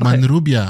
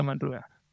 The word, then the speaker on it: Manrubia: Jorge Manrubia. Jorge Manrubia.